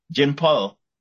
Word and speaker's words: Jinpei: And like you said, Jinpu, I mean, I'm going to say his name properly again. Let me say that. Jinpei.